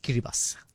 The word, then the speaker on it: Kiribati: Now, many people get this wrong, right? It's spelled Kiribati, but actually pronounced Kiribati.